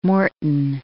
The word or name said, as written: Morton